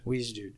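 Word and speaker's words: Wiegedood: Like, it looks like Weege Dude. – Yeah, like D-U-D-E, but it's not spelled that way. – D-O-O-D.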